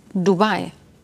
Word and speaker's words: Dubai: Und der kleine Unterschied, das Richtige ist Dubai. Die Betonung.